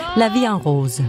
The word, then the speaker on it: La Vie en Rose: Som jeg overhovedet ikke udtalte rigtigt. – Nej. – Hvordan skal det siges? – La Vie en Rose.